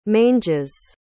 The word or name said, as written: manges